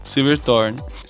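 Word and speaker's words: Silverthorn: Gostou do Silverthorne? – É. – tá boa a pronúncia. Silverthorne. Sinceramente, eu acho que é um livro excepcional, acho que não pode deixar de.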